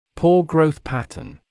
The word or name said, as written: poor growth pattern